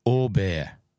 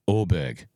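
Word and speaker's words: Åberg: He sent me a voice note where he pronounced it out, and it's like a Orbear, Orbear. Mm-hmm. So there you go. So from this point forward, Ludwig is Orbear. Or I could accept Orberg, but hearing it from a Sweden's first PGA Tour winner two times, Orbear.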